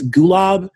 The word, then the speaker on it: Golub: And then Richard Golub, I know I'm pronouncing his name wrong. It's Gulab. Um, he was this author.